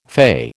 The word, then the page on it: fay: American English